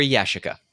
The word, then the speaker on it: Yashica: Do you pronounce it Yashica or Yashica? – It's Yashica.